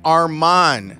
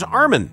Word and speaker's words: Armen: Yeah, because people used to call him Armon. – Armon, when his name is Armin. He changed it to it's from A-R-M-O-N to A-R-M-E-N so people would pronounce it correctly.